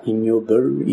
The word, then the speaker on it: Ig Nobel: En anglais, ça s'appelle, on prononce ça ignoble.